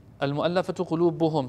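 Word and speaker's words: المؤلفة قلوبهم: He says that when we look at the seerah of the second Khalifa, we notice that the mu'allafatu qulubuhum, mu'allafat qulubihim, or um, al-mu'allafatu qulubuhum, excuse me, I've been saying it wrong, Al-mu'allafatu qulubuhum.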